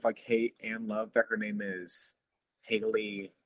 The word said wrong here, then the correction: Halle: Her first name is spelled H-A-L-L-E. Yeah, it's Halle.